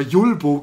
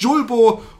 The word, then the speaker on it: Julbo: Ich habe es übrigens immer falsch ausgesprochen, wie ich jetzt gerade erfahren hatte in dem Telefonat. Ich habe immer Julbo gesagt. Völliger Schwachsinn. Es heißt Julbo und Light.